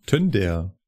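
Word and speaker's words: Tønder: Nach wird das O, was so durchgestrichen ist, als Ö ausgesprochen? Was willst du denn sagen? Padborg? Nee, Tonder. Tönder. Achso, Tönder. – Tönder. Ja. – Tönder. Ja. Ha! Dann mhm. wird es als Ö ausgesprochen. Tönder.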